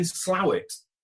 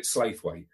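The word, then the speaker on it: Slaithwaite: Well, I'm in Slawit, which I got told off horribly for when I called it Slaithway. So it's Slawit.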